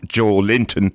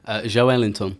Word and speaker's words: Joelinton: It's not Joelinton. – Give us the correct one. – Joelinton.